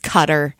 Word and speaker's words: Qatar: And then also on here was Qatar, which I say wrong as well. It's Cutter.